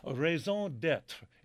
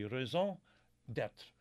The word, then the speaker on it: raison d'être: Raison d'être. It's a reason to be. It's a French term, reason to be. Raison d'être.